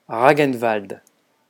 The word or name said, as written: ragnvald